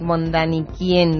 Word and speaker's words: von Däniken: vais a hacer referencia al libro de Erik von Däniken, no sé si lo pronuncio bien.